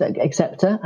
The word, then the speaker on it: Xceptor: Um, and tell us a bit about Xceptor. I'm pronouncing that correctly, I think. Right? – Yeah, it is Xceptor, absolutely.